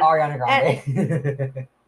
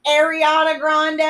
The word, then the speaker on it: Ariana Grande: Ariana Grande. Said her name wrong. – God! I don't care! – I care. – I know you do. Ariana Grande.